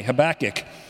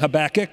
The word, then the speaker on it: Habakkuk: And some of you have asked me, wait a minute, I thought it was, well, we've been saying Habakkuk, but others Habakkuk. And if you want to get technical, if you want to pronounce it in Hebrew, it would be Habakkuk.